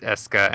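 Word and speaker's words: Escha: No, it's Eska and Logi, and I refuse to accept that those are the way that those two names are pronounced, and yet they are.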